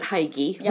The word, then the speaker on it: hygge: Is it H-Y-G-G-E? – Yes. – Oh, yes. – Okay, so for everybody that has seen that word and calls it hygge, like is what I thought. – Yes. – But it's actually hygge.